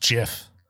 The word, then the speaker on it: GIF: He said it's pronounced JIF.